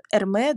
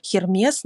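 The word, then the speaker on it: Hermès: Эрме, да, она через H пишется, через Х, так скажем, да, просто это Х на французском, во французском языке не переводится, поэтому не Хермес, не Гермес, да, а получается, что Эрме.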